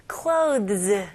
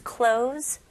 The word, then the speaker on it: clothes: 여기서도 똑같이 발음을 clothes 이렇게 할 필요 없습니다. Z 발음으로. His clothes got wet.